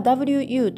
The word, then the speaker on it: 午: WU というふうに書きます。第三声、低く沈む音になります。これ関数字のうと全く同じ発音になります。